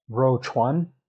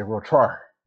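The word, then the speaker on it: Ro Chuan (肉串): They got these little skewers called Ro Chuan. If you use the standard Putonghua Mandarin pronunciation. But up here with the Dongbei, the Northeast dialect, they say Ro Char.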